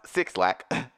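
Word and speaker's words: 6LACK: She signed with Powerhouse Interscope and Love Renaissance, a label based in Atlanta and home to Black, should be pronounced Six Lack, BRS Kash of Throat Baby fame.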